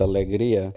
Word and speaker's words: Alegría: By the way, Alegría, pronounced Alegría, is Spanish for happiness.